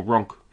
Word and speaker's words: Ronk: who drove along to Ronki, or Ronk, however it's pronounced. – Ronk, Ronk, Ronk, Ronk. Yeah, we've got to get that one right.